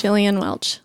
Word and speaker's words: Gillian Welch: I think it's Gillian Welch.